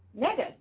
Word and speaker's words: Negus: N-E-G-U-S. Mega.